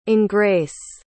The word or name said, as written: engres